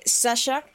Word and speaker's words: Zatja: De Sasha, que la pueden encontrar en Instagram a su negocio como Sadha. Punto, no, perdón, by Sadha. Porque, o sea, se dice Sasha, pero se escribe Z-A-T-J-A.